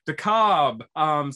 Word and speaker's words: DeKalb: DeKalb.